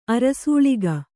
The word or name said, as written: ಅರಸಾಳಿಕೆ